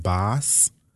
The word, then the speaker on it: Bas: The only person I think that performed that I was introduced to, I think, is a guy, I think his name is pronounced Boss. B-A-S.